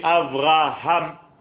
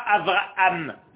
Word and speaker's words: Avraham: Il faut le dire et il faut prononcer, ce qui est difficile pour les francophones, le hé. C'est pas Avraham, comme s'il y avait quatre A.